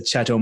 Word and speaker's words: Chattel: First of all, did I do that right? Is there other ways to pronounce it? Explain. – No, you've nailed it, mate. That's what we call it anyway. That's how we pronounce it. I've heard many a different pronunciation, but let's go with Chattel Mortgages.